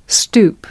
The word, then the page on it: stoep: American English